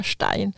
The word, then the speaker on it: Stein: Så vi hade här om året en besök av, nej, det är flera år sedan nu, men Murray Stein, eller Stein kanske de kallas, han är amerikan, men jag uttalar hans efternamn på tyska, Stein.